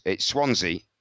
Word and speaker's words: Swansea: Swansea is it's, well, it's Swan C. – It looks like it sounds, yeah. – With a hard S, so it's Swansea.